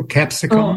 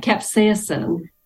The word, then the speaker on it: capsaicin: Capsicum? – Capsaicin.